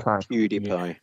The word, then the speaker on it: PewDiePie: PewDiePie.